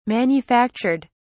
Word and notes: manufactured: British English

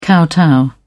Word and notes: kowtow: British English

